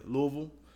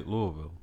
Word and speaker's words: Louisville: Louisville? Yeah, Louisville. As the natives of Louisville would say. – Did you? – Is that how they say it? – Yeah, that's how they say it. – Is that how they say it? – We say Louisville. Yeah.